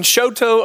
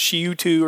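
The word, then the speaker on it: Chouteau: And so I got to a stoplight And I was in Choteau, Oklahoma. So if you're not from Oklahoma, you pronounced that when you saw it on the news, Shiutu or something like that.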